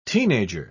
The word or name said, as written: teenager